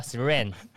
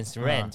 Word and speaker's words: SRAM: 它是英文是 S R A M 嘛，嗯，所以我都会讲说 S R 它不能够接在一起的。对所以我想说 SRAM，SRAM，然后我记得我们公司说，你英文好像不是很好哦。我们公司叫 SRAM，就是速联 SRAM，就是连在一起这样子。